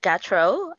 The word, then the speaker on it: Gautreaux: It's uh, Miss Laura Gautreaux.